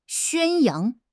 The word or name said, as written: 宣扬